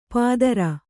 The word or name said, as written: ಪಾದರ